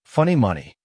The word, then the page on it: funny money: American English